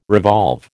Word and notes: revolve: American English

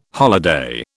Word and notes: holiday: British English

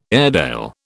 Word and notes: Airedale: British English

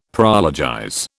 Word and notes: prologize: British English

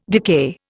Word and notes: decay: Indian

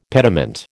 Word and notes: pediment: American English

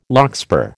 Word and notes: larkspur: American English

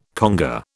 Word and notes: conga: British English

